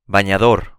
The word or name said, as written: bañador